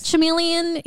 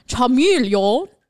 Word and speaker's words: Charmeleon: Charmeleon is how you say the Pokemon's name. So Charmeleon.